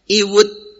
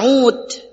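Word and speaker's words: ud (عود): Kenapa antum membacanya ud, tidak antum baca awad, tidak antum membaca aywad, tidak antum membacanya iwud? Kenapa antum membacanya ud? Ya karena antum tahu, antum pernah mendengar, antum pernah menyimak, kalau ada kalimat ain, kemudian waw, kemudian dal, itu dibacanya adalah ud.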